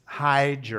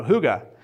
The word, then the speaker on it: hygge: It's spelled H-Y-G-G-E. So you would think it's hyge or, you know, but it's pronounced hygge.